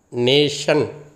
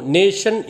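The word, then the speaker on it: nation: நேஷன் என்று எழுதுகின்ற பொழுது டீ வரும் ஆனால் டீயை உச்சரிக்க மாட்டோம்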